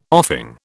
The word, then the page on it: offing: British English